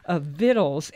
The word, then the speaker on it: victuals: And it's spelled, it looks like victuals, but only some Yankees would say that, I think. We're talking about vittles, as it's known in Appalachia, and or Appalachia.